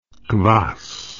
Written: quass

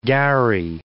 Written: gharry

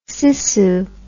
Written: sissoo

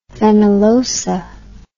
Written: fenollosa

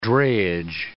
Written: drayage

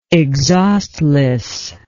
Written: exhaustlessness

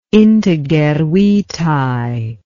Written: integer vitae

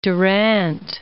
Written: Durant

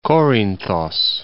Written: Korinthos

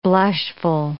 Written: blushful

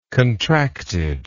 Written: contractedness

